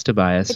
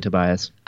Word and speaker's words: Tobias: Oh, it's Tobias. It's Tobias, really? – Definitely Tobias.